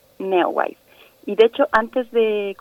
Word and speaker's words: Neowise: Y nos vas a comentar en esta mañana sobre el cometa Neoways, si lo estoy pronunciando bien, si no, tú corrígeme por favor, ¿de qué se trata? – Sí, bueno, estas palabras así extrañas es válido pronunciarlas como podamos. Okay. pues sí, yo les quiero hablar de Neowise, eh, que está captando nuestra atención desde hace ya varias semanas.